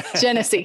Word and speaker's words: Genesee: This was a case that John tried in 2019 to verdict in the Circuit Court of Genesee County. Rhymes with Hennessy. Genesee right.